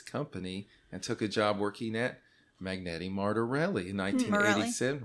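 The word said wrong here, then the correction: Marelli: Morelli. – Yeah, that's how you say it.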